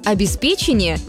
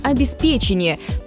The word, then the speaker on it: обеспечение: Большинство из них утверждают правильный вариант «обеспечение». Правда, в начале нашего века появились несколько словарей, допускающих вариант «обеспечение», как разговорный и даже как равноправный.